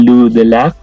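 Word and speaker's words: Lou de Laâge: Vamos a comenzar con Etoile, esta serie de los paladinos, protagonizada por Luke Kirby, Charlotte Gainsbourg y Lou Delac, se pronuncia así.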